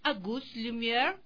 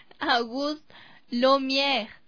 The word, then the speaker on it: Auguste Lumière: En 1907, en París, Auguste Lumière presenta la fotografía en color. Se ríe. Se me ríe cómo pronuncio. ¿Cómo sería? – Auguste Lumière. – Ah, Auguste Lumière.